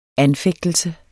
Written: anfægtelse

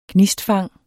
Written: GNISTFANG

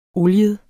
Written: oliet

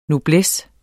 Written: noblesse

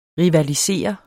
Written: rivalisere

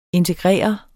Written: integrere

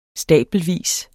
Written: stabelvis

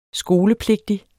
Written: skolepligtig

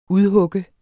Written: udhugge

